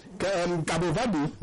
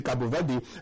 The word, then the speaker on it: Cabo Verde: Cabo Verde, like they will pronounce it themselves, we call it Cape Verde, but they call it Cabo Verde.